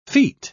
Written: feat

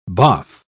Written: boff

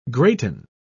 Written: greaten

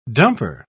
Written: dumper

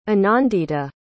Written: anandita

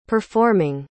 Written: performing

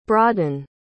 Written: bradan

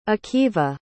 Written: akiva

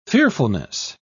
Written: fearfulness

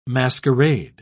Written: masquerade

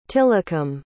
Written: tillicum

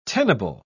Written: tenable

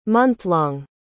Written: monthlong

